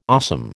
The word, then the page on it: awesome: American English